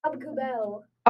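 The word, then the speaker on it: Aubé-Kubel: Doesn't that look Adube Kuble? Isn't that what it is? Adube Kuble? – It's Abkubel. – Abkubel. Ooh, fancy. – You get the D from. No. Okay, Abkubel?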